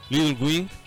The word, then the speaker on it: Little Wing: Little Wing. Que no sé si esté bien pronunciado, aquí mis, mis mis amigos me van a ayudar. Correctamente bien pronunciado.